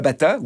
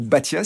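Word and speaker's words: Baťa: Dans l'ouvrage, on parle de Thomas Batta, ou Batia, si on le prononce avec l'accent tchèque,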